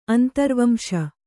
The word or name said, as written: ಅಂತರ್ವಂಶ